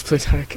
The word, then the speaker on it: Plutarch: How do you pronounce his name? – Plutarch? – Yeah, it's Plutarch.